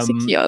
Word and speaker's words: Siquijor: Ich weiß nicht, wie es ausgesprochen wird. – Ja, Siquijor ist richtig.